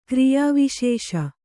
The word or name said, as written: ಕ್ರಿಯಾವಿಶೇಷ